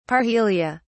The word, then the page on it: parhelia: British English